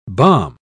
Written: bombe

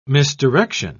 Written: misdirection

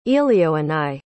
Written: elioenai

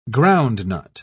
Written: groundnut

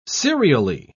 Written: serially

